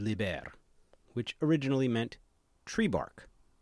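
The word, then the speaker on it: līber: Liber, excuse me, which means free, long I.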